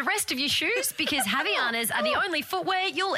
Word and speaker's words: Havaianas: Havaianas, is that how you say it? – Yep. – Up your footwear game with Havaianas.